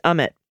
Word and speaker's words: Amit: Amit Mehta is pronounced Amit. Equal emphasis on both syllables.